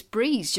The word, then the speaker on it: Breese: Well, it's just, I think it's Breese, just because, Breese. – Yeah, in, because we're in Wales, by the way.